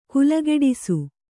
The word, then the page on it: ಕಾಲ್ಗಿಡಿಸು: Kannada